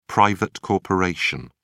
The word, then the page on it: private corporation: British English